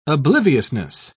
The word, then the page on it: obliviousness: British English